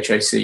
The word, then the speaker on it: Chace: But if you Google Pandora's Brain or Google my name, which is spelled Chace, there's not too many people with my name around, so I'm not too hard to find.